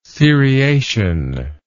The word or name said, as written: seriation